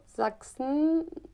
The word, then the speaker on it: Sachsen: Tak třeba Sachsen nebo Wachsen, že píše se to vlastně jako ch, ale vyslovuje se to jako k.